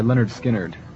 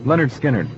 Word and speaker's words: Lynyrd Skynyrd: It's by Lynyrd Skynyrd.